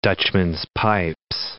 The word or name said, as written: Dutchman's-pipes